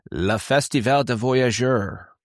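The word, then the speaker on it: Festival du Voyageur: You know what we have here in Winnipeg in the next couple of weeks is called, it's in French, it's pronounced Le Festival de Voyageurs, which is basically celebrating the pioneer era of the fur traders in this area.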